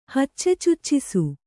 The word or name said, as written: ಹಚ್ಚೆಚುಚ್ಚಿಸು